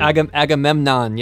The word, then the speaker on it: Agamemnon: Agamemnon, yes.